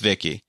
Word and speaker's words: VICI: voice input child identicant, uh, and it's pronounced V I C I, but it's pronounced Vicky.